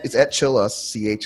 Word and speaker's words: Chillus: It's at Chillus, @CHILL_US, which is how you pronounce my last name.